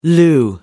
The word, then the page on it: lieu: American English